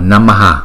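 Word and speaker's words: namaḥ: Chữ H có dấu chấm ở dưới thì chúng ta đọc lấy chữ nguyên âm ngoài trước chúng ta choàn ra ngoài sau, chúng ta đọc là Namaha.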